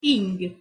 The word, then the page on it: ing: Hungarian